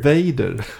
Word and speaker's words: Vader: som skoningslöst och blodigt eh, korrigerade mig, tillrättavisade mig faktiskt, för ja. mitt uttal av Darth Wader. – Vader. Jag säger Wader. Ja men det är väldigt svenskt.